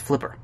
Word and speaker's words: FLPR: Okay, Enough the inside jokes, and to those of you who don't know, do a web search for IRAF and FLPR, which is pronounced as Flipper.